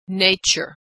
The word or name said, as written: nature